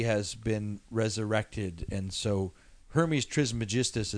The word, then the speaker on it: Hermes Trismegistus: Hermes Trismegistus.